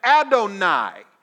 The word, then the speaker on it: Adonai: And so now this guy, Adonizedek, that first part of his name when you take it away from the Zedek part, can be pronounced and used as Adonai.